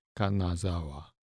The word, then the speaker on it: Kanazawa: I think Kanazawa might be good. Kanazawa. – How do you spell it? – K-A-N-A-Z-A-W-A. Kanazawa.